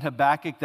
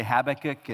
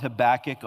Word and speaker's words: Habakkuk: And I'm pronouncing it Habakkuk. That'll, you know, for some of you, you maybe say Habakkuk, and use this if someone questions the way you pronounce it. It's a loan word. His name is from the Akkadian, and we're not sure how to pronounce it, so that just kind of ends the argument.